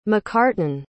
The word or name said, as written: macartan